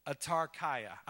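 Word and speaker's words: autarkeia: Atarkaia.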